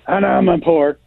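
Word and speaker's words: Amanpour: Please get her name right. It's Anamapour.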